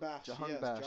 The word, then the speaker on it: Jahanbakhsh: Let me take a wild guess, because your boy Ali Reza Jahanbakhsh. – Is that how you pronounce it? – Jahanbakhsh. Jahanbakhsh.